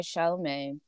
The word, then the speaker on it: Chalamet: So she meets this other guy, Chalamet. She ditches Sidekick. That is how you say his name.